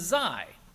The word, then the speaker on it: Xi: And the other letter they skipped is Xi, which is spelled X-I, which is also the name of the leader of China, President Xi, X-I.